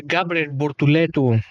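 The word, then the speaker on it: Gabriel Bortoleto: Γκάμπριελ Μπορτουλέτου, όπως είναι η προφορά, από 16ος 10ος.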